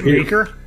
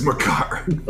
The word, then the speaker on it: Makar: That Maker kid is pretty good, man. – Is it Maker? – No, it is Makar.